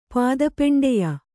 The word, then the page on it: ಪಾದಪೆಂಡೆಯ: Kannada